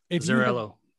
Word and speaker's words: Azzarello: Brian Azzarello? Azzarello? – Azzarello.